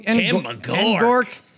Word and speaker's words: Ngork: N-G-O-R-K? I don't want to offend him or anyone else. – Tim Ngork.